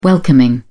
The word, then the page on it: welcoming: British English